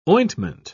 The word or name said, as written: ointment